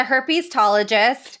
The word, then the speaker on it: herpetologist: His father was um, an expert with a strong interest in reptiles and amphibians, which I believe is uh, a, a, herpetologist.